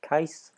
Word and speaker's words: Kais: Hey, and the pronunciation the others are going with is Case, but the more accurate pronunciation as an Arabic name would be more rhyming with mice or dice, as in Kais.